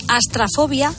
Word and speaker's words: astrafobia: De hecho, la fobia a las tormentas se conoce como astrofobia, astrofobia, no lo digo mal, astrafobia o Brontofobia.